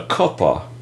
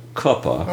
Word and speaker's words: Cooper: Well, you know, there are two main rivers in Charleston, the Ashley and the Cuppa, as they say it here. It's spelled C-O-O-P-E-R, but they call it Cuppa.